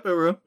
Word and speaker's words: Uru: Turns out it was made of Uru. Does that sound like I pronounced it right? – Yeah. – Yep, Uru. Yep. – U-R-U.